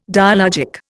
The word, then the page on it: dialogic: Indian